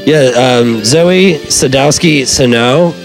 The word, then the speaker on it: Zoi: Just check out Zoe. It's spelled really cool. Z-O-I is her first name. – Zoe.